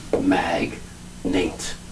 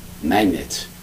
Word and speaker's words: magnate: Notice the spelling. M-A-G-N-A-T. Magnate. Magnet. But we pronounce it like a magnet, putting things together. Yeah. It's magnet. Magnet.